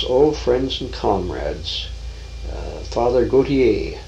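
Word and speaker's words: Gauthier: G-A-U-T-H-I-E-R. I don't know how you pronounce it. There's a French and a Belgian way of doing it. – I've heard it pronounced Gautier. – Gautier. The Belgians, more of an accent on the last syllable.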